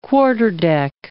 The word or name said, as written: quarterdeck